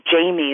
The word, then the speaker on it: Jaimie: My name, Jamie, is spelled J-A-I-M-I-E.